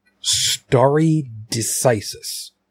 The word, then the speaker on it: stare decisis: It's stare decisis. Did I say that right? Mm-hmm.